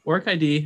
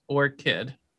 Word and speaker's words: ORCID: But for those who want to know, ORCID has two syllables and a hard C. – What? – Phonetically, it is pronounced as ORCID. I usually tell people it is pronounced like flower ORCID, but spelled without the H.